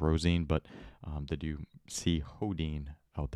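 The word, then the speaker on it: Sjödin: Sjodin, but pronounced Hodin.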